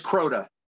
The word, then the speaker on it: Crota: But it's is it Crocia? Crocia by Cro what? – Cro, just Crota. – Crota by Owl Goingback, and um, that that is scary as shit. I will say that Crota yes.